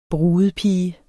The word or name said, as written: brudepige